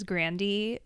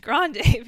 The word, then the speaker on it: Grande: And also she said, like, the actual pronunciation of her name is Grandy, but it's just Americans just assume it's Grande because Spanish is more popular in America.